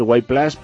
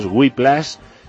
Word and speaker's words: Whiplash: Bien, lo he dicho bien, porque tiende todo el mundo a decir Whiplash, pero es Whiplash, que significa latigazo en inglés,